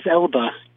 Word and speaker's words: Elba: or Idris Elba. Elba, that's how you say it.